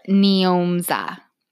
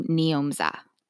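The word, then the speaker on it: Njomza: I'm pretty sure it's Nimza. Hold on. I literally had to. Oh, I'm sorry. Nyomza. Okay, so you pronounce her name Nyomza. And that's N-J-O-M-Z-A.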